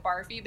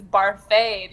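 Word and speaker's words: Barfée: Anyway, Josh Gad's character is like really obnoxious, and his name is spelled Barfy, and everyone calls it Barfy, but he says it's Barfay because there's an accent agoo, like rosé.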